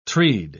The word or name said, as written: treed